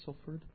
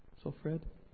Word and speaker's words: Salford: Am I saying it right? Salford? Salfred? No. You guys say it a little differently. I read it and I'm like, is that what they're saying? Yeah, you pronounce like an O.